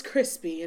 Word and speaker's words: Chrispy: And my name is Chrispy,